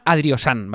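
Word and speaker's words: Adrihosan: A ver, el patrocinador de hoy se llama Adriosan, ¿vale? Adriosan se escribe Adri y después Josan, es decir, va con H, va con una H intercalada que no se pronuncia, ¿vale?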